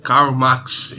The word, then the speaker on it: Karl Marx: Vamos estar tá dando início à nova série sobre Karl Marx. É isso aí, né? Falei certo? Falou certo.